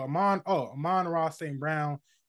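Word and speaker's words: Amon-Ra St. Brown: Maybe even an Amon St. Ra Brown. I'm not gonna say his name, bro. Amon Ra St. Brown.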